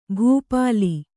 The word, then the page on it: ಭೂಪಾಲ: kannada